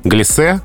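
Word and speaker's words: глясе: Глисе — твердое «э», одна «с» и ударение на последнем слоге.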